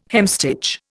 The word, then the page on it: hemstitch: Indian